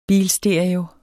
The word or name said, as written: bilstereo